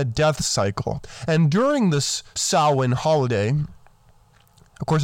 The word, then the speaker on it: Samhain: Now, if you look up the spelling, it's S-A-M-H-A-I-N, but it's pronounced Samhain.